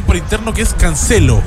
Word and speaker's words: Cancelo: Busqué la pronunciación, incluso para estar seguro, busqué la pronunciación. Y sí, es Cancelo.